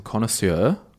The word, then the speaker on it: connoisseur: But I will say, if you're listening out there, I want you to grab some connoisseur. How do you say that word? – Connoisseur. – Connoisseur. Connoisseur. I always say it like Malcolm Turnbull.